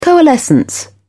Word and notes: coalescence: British English

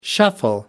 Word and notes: shuffle: American English

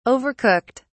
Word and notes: overcooked: British English

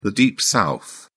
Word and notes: Deep South: British English

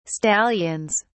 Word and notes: stallions: British English

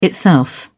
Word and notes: itself: British English

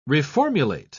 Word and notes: reformulate: British English

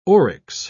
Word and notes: oryx: British English

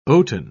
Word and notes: oaten: British English

